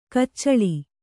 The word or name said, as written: ಕೊಚ್ಚಲು